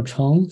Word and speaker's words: Chong: Yeah, people can search my name, Samuel Chong, C-H-O-N-G, and the title of the book, Theoba Prophecy.